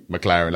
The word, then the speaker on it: McLaren: McLaren, like a sports car. – McLaren, I see it. No, I said it, but it's spelled M-C-L-E. – L-A-R-E-N, just like the sports car. – Oh, L-A-R-E-N.